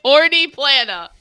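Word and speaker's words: Horní Planá: That's not how you pronounce that at all, I'm sure. I know some Czech folks. I'll ask them about what they know about the story. – No, it's Horny Plana. Horny.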